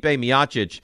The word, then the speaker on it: Miocic: But it's Stipe Miocic and Daniel Cormier complete their trilogy at UFC 252 on ESPN+.